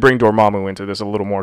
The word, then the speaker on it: Dormammu: Dormammu.